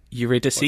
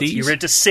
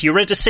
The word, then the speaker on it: Eurydice: Eurydice. – Eurydice. It's Eurydice. He's got it wrong. Yep. He's added an S on the name.